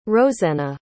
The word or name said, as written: rosenna